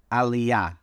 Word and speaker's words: Aliyah: and then we'll get into the whole Aliyah. Did I pronounce it right, Zevi? – You did. That was excellent.